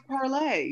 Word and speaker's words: Parler: It should be pronounced parlay, you stupid American.